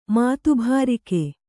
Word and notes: ಮಾತುಭಾರಿಕೆ: mātubhārike; Kannada

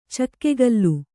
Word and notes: ಚಕ್ಕೆಗಲ್ಲು: cakkegallu

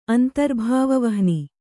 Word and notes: ಅಂತರ್ಭಾವವಹ್ನಿ: Kannada

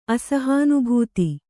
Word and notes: ಅಸಹಾನುಭೂತಿ: Kannada

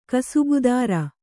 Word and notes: ಕಸಬುದಾರಿ: kasabudāri

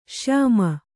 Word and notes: ಶ್ಯಾಮ: kannada

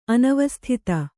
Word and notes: ಅನವಸ್ಥಿತ: anavasthita

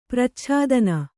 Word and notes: ಪರಿಚ್ಛೇದನ: paricchēdana

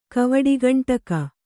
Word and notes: ಕವಡುಗಂಟಕ: kavaḍugaṇṭaka

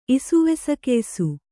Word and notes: ಇಸುವೆಸಕೆಯ್ಸು: kannada

